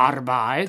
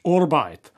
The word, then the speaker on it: Arbeit: Tak třeba německé slovo Arbeit. Takže česky práce. Ve Vídni málo kdy zaslechnete vyslovené jinak než Arbeit.